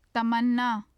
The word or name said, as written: tamanna